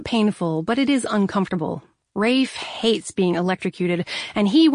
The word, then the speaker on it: Ralph: Rat number one, let's call him Ralph the Rat, only pronounced Rafe, like Rafe Fiennes.